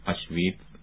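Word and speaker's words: Auschwitz: تذکر تلفظ کلمه های آشویت و داخاو صحیح می باشند.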